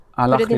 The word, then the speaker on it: Al-Ahli: Hjälp mig med uttalet på klubben. – Al-Ahli låter bra.